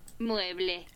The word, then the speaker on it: Mueble: Mueble.